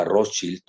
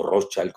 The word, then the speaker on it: Rothschild: Y me llamó mucho la atención que Macron, que recuerden ustedes, él fue funcionario de la banca Rothschild o Rothschild, como lo quieran pronunciar, si lo pronuncian en alemán o en, inglés estadounidense, porque los británicos lo pronuncian diferente, eh, pues él fue empleado de la banca Rothschild. Entonces, eh, yo lo digo a la alemana, eh, más que a la estadounidense, que saben ustedes, se quiere decir escudo rojo, de ahí viene la palabra Rothschild.